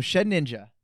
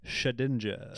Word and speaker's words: Shedinja: Okay, so Shed Ninja. – No, you know it. – No, that's not the name. – What is? How do you say it? – Shedinja.